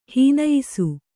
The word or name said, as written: ಹೀನಯಿಸು